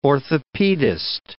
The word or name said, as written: orthopedist